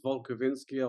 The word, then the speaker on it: Wolkowinski: My on Instagram, I've just got used my surname, which is Wolkowinski. I'll spell that Wolkowinski.